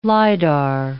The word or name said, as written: lidar